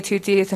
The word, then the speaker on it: Twoteeth: My name is Kelly Tutteth, and for the record, you spell that T-W-O-T-E-E-T-H.